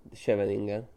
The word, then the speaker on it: Scheveningen: Scheveningen. – Scheveningen.